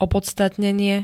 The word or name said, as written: opodstatnenie